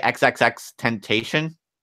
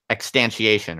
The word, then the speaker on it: XXXTentacion: Is that how you pronounce it? Or do you just like elide the extra X's? – No, you definitely pronounce the X's. – You say XXXTentacion? – XXX, yeah, 3 X's.